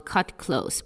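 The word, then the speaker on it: Kut Klose: So I found this one very cool R&B song from 95 by a girl group called Cut Close, but spelled with a K.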